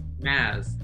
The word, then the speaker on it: Mads: Mads Mikkelsen. Pronounced Maz.